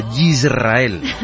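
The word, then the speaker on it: Yisrael (ישראל): Bienvenida a Israel, a Yisrael. – ¿A Israel? – Yisrael. – A Yisrael. – Yisrael, que así se escribe en hebreo. Yisrael, okay, con Y de yuca.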